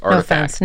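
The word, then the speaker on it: RTFKT: There's, I don't know if we talked about this in the last one, but there's a company called Artifact, and it's spelled really weird, RTK, whatever, FKT, I don't know, I can't spell. – Neither can they, apparently, because it's pronounced Artifact.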